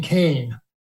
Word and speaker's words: Kean: She pronounces her name Kane, but she spells it K-E-A-N, so it looks like Keen.